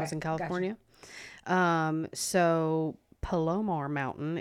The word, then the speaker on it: Palomar: I drove up to, I might mispronounce this, Palomar Mountain, P-A-L-O-M-A-R, Palomar.